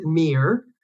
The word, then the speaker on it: Myrr: So I probably would have said, which I've said to a few people, is that, you know, I pronounce it Myrr. It's spelled M-Y-R-R.